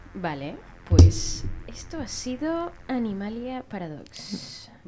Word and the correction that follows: Animalia Paradoxa: Animalia, ¿lo he dicho bien? – Sí, Animalia Paradoxa.